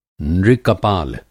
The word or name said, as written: नृ-कपाल